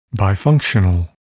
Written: bifunctional

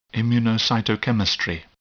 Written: immunocytochemistry

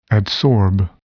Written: adsorb

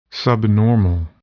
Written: subnormal

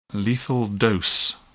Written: lethal dose

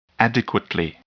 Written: adequately

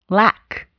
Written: lack

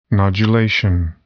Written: nodulation

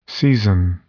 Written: season

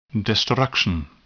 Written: destruction

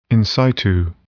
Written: in situ

